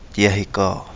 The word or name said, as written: DiAhiGaa